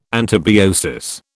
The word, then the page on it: antibiosis: British English